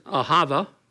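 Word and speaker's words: ahava (אהבה): It's pronounced as ahava, like I'll have a pizza.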